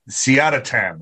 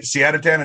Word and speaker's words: Siadatan: I am joined with a very special guest, Ali Siadatan, right? Siadatan, is that how you say it? – That's it.